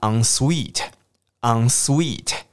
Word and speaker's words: ensuite: 这个字从法文来，很多法文 en 开头的字都念成 ang ang ang 的声音，因为有时候我们看到 en 会很自然念成 n，对不对？好，比方说像套房、雅房，那个叫 ensuite，ensuite。